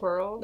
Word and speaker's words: Quirrell: Because literally, how do you say it? Quirrell? Squirrel? – Yeah, Quirrell. – That's how I say it. It's like squirrel.